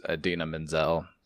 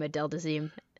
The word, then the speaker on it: Idina Menzel: Sandler's wife in the film was Idina Menzel from Uncut Gems, too. – Her name is Adele Dazeem. That's a funny joke. It's okay. – Oh. – One time, John Travolta mispronounced her name, Adele Dazeem.